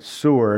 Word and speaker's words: Sur: That's how you pronounce it, Sur. And it doesn't mean like a sewer.